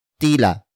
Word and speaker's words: tila: Word for word, it's tila nababasag ang puso niya tila nababasag ang puso niya tila nababasag ang puso niya.